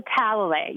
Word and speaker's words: Talalay: Well, and I think one of your other inspirational awards was too, and I hope I say her name right, Rachel Talalay? – Rachel Talalay, yeah.